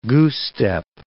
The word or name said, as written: goose-step